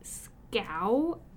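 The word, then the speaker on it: Schow: I finished the Spelled series by Betsy Scow, apparently is how you say it. – Then they need to take the H away.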